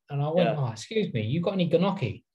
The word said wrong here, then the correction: gnocchi: And she was like, oh, gnocchi. I was thinking, why the fuck would you put a G in front of it if it's not pronounced like that? – Fuck it, them Italians for you, didn't it?